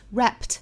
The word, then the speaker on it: wrapped: s 那如果读得快一些呢？Wrapped 这个 t 的发音我们做失去爆破，那是发成 wrap gifts, wrap gifts。但是我们要知道单独发这个词应该怎么发，wrapped, wrapped。